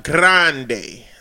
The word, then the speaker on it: grande: Two, it's grande, but you roll the R. Like grande.